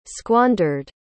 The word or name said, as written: squandered